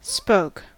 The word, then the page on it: spoke: American English